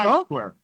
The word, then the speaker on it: Times Square: ya prácticamente en Times Square, ¿no? – Times Square.